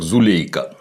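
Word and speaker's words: Зулейха: и в моем русском языке имя звучит как Зулейка. В тюркских языках ударение ставится на последний слог.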